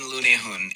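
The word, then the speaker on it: lundehund: We Googled it. This is how you say it. – The Norwegian lunehun is a small to medium-sized dogs. Lunehun.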